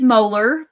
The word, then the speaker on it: Moeller: It's Moeller, but you're Moeller.